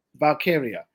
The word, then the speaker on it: Valkyria: And Lyra Valkyria, am I saying her name right? – Valkyria.